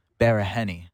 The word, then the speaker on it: Baraheni: Okay, well, the last name you can like enunciate it if you want to. Barahenny.